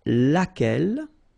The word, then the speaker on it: laquelle: U is not pronounced here, and then you've got this E, double L, E, well, phonetically here, it is the same as what we have here, so laquelle, so the only difference between the feminine and the masculine form is this first part, okay?